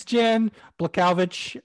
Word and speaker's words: Jan Błachowicz: Blankowicz. – Yeah, you said it wrong. – Some guy named Blah. It's Jan Błachowicz.